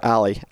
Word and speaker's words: Ally: Is it ally or ally? I, am sure. – I believe it's ally because, and this is this sense. is something I was going to ask you later, but I'll just ask you now. I believe it's Ally because their uh, television commercials, I think, pronounce it that way.